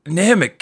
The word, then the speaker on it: Namek: Namek, King Kai.